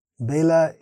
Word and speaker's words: Bela: Bela is Bengali pronunciation of Vela, which is another word for time in Sanskrit.